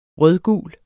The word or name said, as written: rødgul